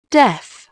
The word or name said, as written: deaf